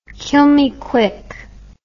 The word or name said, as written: kill me quick